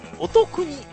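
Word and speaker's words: 乙訓: まあ、このお店はですね、一応新型コロナウイルスと戦うその医療従事者をですね支援しようと、まあこれはあの読み方すいませんね、京都府、お得にって呼ぶらしいですね。おつくんと書いてお得に保健所、京都在住の方、読み方を教えていただきましてありがとうございます。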